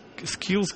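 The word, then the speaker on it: Skeels: Первым ушел Барри Скилс, кстати, Скилс пишется не через дабл L, как вот могло бы по произношению показаться, а Скилс, дабл E в середине.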